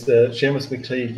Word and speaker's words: McTeague: And Seamus McTeague, am I saying this right now? Because I've called lads' names before and they weren't too happy. – You're all right. It's Seamus McTeague. Yeah, you'll be grand at that.